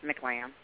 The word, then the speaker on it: McLamb: Am I saying your name right, Shanell? – Yes, McLamb.